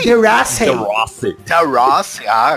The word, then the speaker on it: De Rossi: Sem o De Rossi e sem o Tevez.